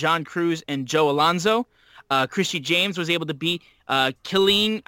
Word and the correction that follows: Kylan: I hope I pronounced that right. Killeen King. – Oh, Kylan King is... – Kylan King. My bad.